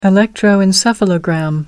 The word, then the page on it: electroencephalogram: American English